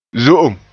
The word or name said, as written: zo'om